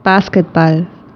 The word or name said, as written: avoañembosarái